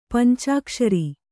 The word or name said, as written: ಪಂಚಾಕ್ಷರಿ